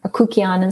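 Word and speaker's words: kykeon: But this guy did massive amounts of research into the connection on Akukion, is how he pronounces it, in Greece for almost 2,000 years.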